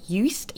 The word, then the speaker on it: Uist: And these two launch sites, one of them was in the Outer Hebrides in Scotland, and I'm going to mispronounce it, but it's called Uist, U-I-S-T, Uist.